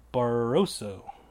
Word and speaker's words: Barroso: Barboso. Barroso? There's no, there's no other B in there. I'm thinking of Barbo, Bar- Barbero, Bar- Bar- Barbosa is who I'm thinking of. I can't even talk. Barroso, Francimar Barroso. Okay, I think that's how you say it, but that's who I'm going with in that that bout.